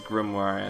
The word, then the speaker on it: grimoire: It looks Grimoire. – I know as a kid that I pronounced it Grimoire, so I had to make sure. – I mean, there is an I in there, to be honest.